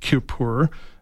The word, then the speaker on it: Kuiper: I think it's Kuiper. It's spelled K-U-I-P-E-R.